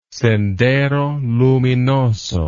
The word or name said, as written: Sendero Luminoso